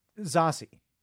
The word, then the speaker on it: Zazie: So Zazie's only been is it Zazie? You know, how do you say it? – Zazie. – Zazie. She's only been acting 7-8 years.